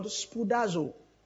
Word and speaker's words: spoudazo: That word study is the word spoudazo. Spoudazo. That's the verb form. The noun of that word is spoude. S-P-O-U. Okay? That's how it's spelled. S-P-O-U. D-A-Z-O, spoudazo, spoude, is just S-P-O-U-D-E.